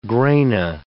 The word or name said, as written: grana